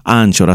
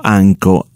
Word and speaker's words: Anchor: Así como también puede aprovechar nuestra nueva aplicación que estamos usando, la cual yo lo digo en español, se escribe Anchor. Anchor, así como, como oye, o Anco, o Anca FM, también otra forma de decirlo, o Anchor FM,